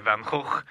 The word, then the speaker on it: Van Gogh: Yeah, which was the Sunflowers by Van Gogh. – Is that how you say his name? – That's how you say his name. Yes.